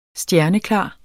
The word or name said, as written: stjerneklar